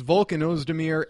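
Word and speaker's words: Volkan Özdemir: Most people didn't even know how to say his name. It's Volkan Ozdemir, and he defeated Ovince St. Preux on short notice, was a unanimous decision.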